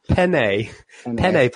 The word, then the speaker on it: penne: Penei pasta. – No, that's not even how that's pronounced. – Pasta? – Penne pasta.